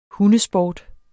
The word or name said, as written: hundesport